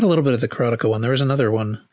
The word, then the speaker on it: Karateka: Well, I just happened to have, I watched this video with Jordan Mechner, and he said Karateka. – Yeah. No, I know, because he's right.